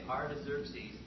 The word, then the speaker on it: Artaxerxes: Artaxerxes is the word Xerxes, X-E-R-X-E-S, with a prefix Arta, A-R-T-A. So you just say Artaxerxes. And these men have to have surnames because there's more than one Artaxerxes, more than one Darius in history. – So his name is Artaxerxes Longimanus, L-O-N-G-I-M-A-N-U-S.